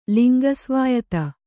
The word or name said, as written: ಲಿಂಗಸ್ವಾಯತ